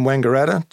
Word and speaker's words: Wangaratta: Did she still live in, I'm going to say this wrong, Wangaratta? Wangaratta? – No, she lived in Melbourne. I lived in Wangaratta till I was, I think, 12, and then left and went to Melbourne.